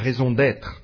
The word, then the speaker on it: raison d'être: That means, it's a French expression which I should pronounce a bit better, a raison d'être, the very reason of our existence.